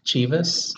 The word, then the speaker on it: Chivas: How do you say that? – I would say Chivas.